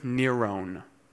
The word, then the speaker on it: Nero: There are two ways of saying Nero. The first is a Hebrew pronunciation, Kaiser Neron, which adds up to 666. The second is a Greek pronunciation, Kaiser Nero, which we're more familiar with, and that adds up to 616.